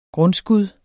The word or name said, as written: grundskud